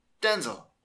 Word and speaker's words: Denzel: The emphasis is on the first syllable. Denzel.